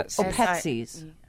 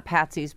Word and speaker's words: Petsies: P-E-T-S-I-E-S. – Oh, Petsies. Like not Patsies, but Petsies.